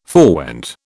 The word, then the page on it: forewent: British English